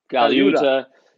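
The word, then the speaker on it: Colaiuta: Because he's had, Yeah, his drummers have been Dean Castronovo, Vinny Cagliuta. Yep. Am I saying that right? – Yeah. – Cagliuta.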